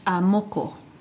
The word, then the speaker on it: moko: It's moko.